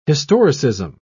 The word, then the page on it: historicism: British English